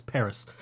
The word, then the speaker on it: PVRIS: They're called Paris, and it's spelled P-V-R-I-S, Paris.